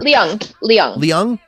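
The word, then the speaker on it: Leung: Yep, Cho Chang, played by Katie Luing, Luang. I think that's how you pronounce it. I can't remember. – Liang. – Okay, good. Yeah. Okay, I'm glad I got that right.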